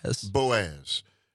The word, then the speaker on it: Boaz: And Boaz, is that how it's pronounced? – Boaz.